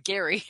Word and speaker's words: Gerry: And for the preview for next week, it's Gary. I keep saying Jerry because his name is spelled Gerry.